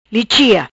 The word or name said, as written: Lichia